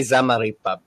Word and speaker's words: Zamarripa: That's why I tell people all the time, I'm like, you can say Zamarripa, it's okay. I'm not going to get offended because you don't pronounce it with a Spanish accent. – Yeah, Zamarripa.